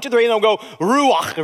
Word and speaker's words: ruach: The word Spirit is the word, the Hebrew word, ruach. Now, if you're going to say it right, you got to go, kah, kah, which means everybody's going to end up with a coronavirus before the service is over.